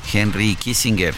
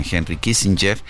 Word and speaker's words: Kissinger: Henry Kissinger. Esa es la pronunciación correcta, la pronunciación alemana. En Estados Unidos lo pronuncian Henry Kissinger. Eh, él mismo usaba esa pronunciación.